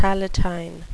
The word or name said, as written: palatine